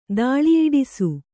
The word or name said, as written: ದಾಳಿಯಿಡಿಸು